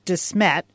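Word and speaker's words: De Smet: He was the founder of DeSmet. Is that how you pronounce the name of that town? – Yeah.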